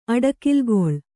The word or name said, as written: ಅಡಕಿಲ್ಗೊಳ್